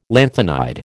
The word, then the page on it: lanthanide: American English